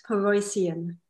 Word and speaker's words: Paroissien: My last name, a bit of a tongue twister, is pronounced Paroissien.